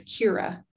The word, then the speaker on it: Kira: It's spelled like Kyra, but it's Kira is how they say it.